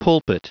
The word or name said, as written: pulpit